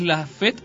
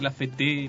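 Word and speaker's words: La Fête: Es La Fet porque está el acento en la primera E, no es La Fete.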